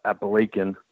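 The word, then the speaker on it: Apalachin: It took place in 1957, where in the small town of Appalachian, that's how they pronounce it there, and in upstate New York, this state trooper, New York state trooper named Ed Croswell, he sees this really incredible car.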